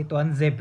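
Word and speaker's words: Tuanzebe: Tuan Zeb itu bacanya Tuan Zeb. Tuan Zeb itu panggilan akrab.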